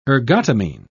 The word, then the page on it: ergotamine: British English